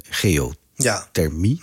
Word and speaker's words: geothermie: Zonne-energie, windenergie en geothermie. – Ja. – Zeg ik het goed? – De geothermie, Je hoort mij twijfelen over de goede naam. – Geothermie is goed.